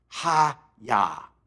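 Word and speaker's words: hayah: In Hebrew, it's pronounced ha-yah. Almost like you karate chop someone. Ha-yah!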